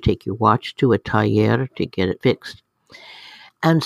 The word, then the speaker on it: taller: And I would see this word, T-A-L-L-E-R, all over the place. Taller. What in the heck is a taller? Well, it's pronounced taller in Spanish, and it really means a shop, a place where you get things fixed.